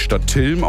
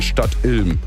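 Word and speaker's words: Stadtilm: Ein Kollege hat eben gesagt, es wird nicht Stadt-Ilm ausgesprochen, sondern Stadt-Ilm.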